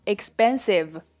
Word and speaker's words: expensive: 第二个 expensive 昂贵的，这里的 p 被浊化成了 b，所以可以读成 expensive expensive。